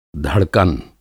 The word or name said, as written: धड़कन